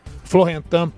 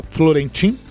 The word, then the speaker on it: Florentin: E entre os jogadores da seleção da Guiné está o zagueiro Florentin Pogba. Alguns podem falar Florentin, que é assim que se escreve, mas em francês, Florentin, né?